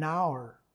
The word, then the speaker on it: hour: Asimismo, hay una excepción para el uso del artículo indefinido en, que en este caso sería que se usa con palabras que comienzan por H, pero solo cuando ésta no se pronuncia. Como por ejemplo, an hour, una hora, a hospital, un hospital.